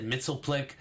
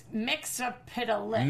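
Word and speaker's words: Mxyzptlk: I loved the pronunciation. I felt like it was the clearest because I've said Mixel Plick for most of my life. – I suck at it. – Mixel Piddalick. – It's Mix-a-Piddalick.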